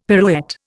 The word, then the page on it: pirouette: Indian